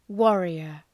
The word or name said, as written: warrior